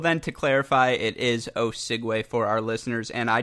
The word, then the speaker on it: Osigwe: So I worked on a long time on that pronunciation. I think I saw a video with the USTA where you said someone once pronounced it Osegue or something. – Yeah, I've gotten all kinds of things like Osage, Osigwe, like there's just so many different pronunciations that I get every single time, whether it's from refs, commentators, players.